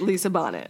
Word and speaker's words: Bonet: Sorry, I didn't realize I totally butchered her name, Lisa Bonet. Lisa Bonet. Bonet.